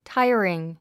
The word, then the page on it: tiring: táiəriŋ; タァイアリン